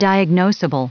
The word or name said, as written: diagnoseable